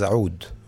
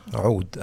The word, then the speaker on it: oud: Yeah, so actually the name pronounced correctly in Arabic is oud. With the ayn, in your throat. – Yeah, ayn, oud.